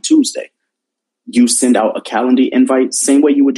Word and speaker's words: Calendly: It's spelled C-A-L-E-N-D-L-Y, so Calendly.